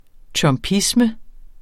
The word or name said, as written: trumpisme